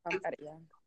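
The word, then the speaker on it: Santería: Am I saying it right? – Santeria. Yep.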